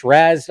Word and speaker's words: Ra's: I know people like always go back and forth with like how you say his name, not the full name, and obviously Ra's al Ghul. But if you shorten it, you use Ra's or Raz.